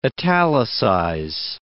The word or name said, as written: italicize